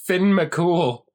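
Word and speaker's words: Fionn mac Cumhaill: And O-U-G-H is... – Augs. – Well, written out, it looks like Fionn McCumail. And it's pronounced Finn McCool.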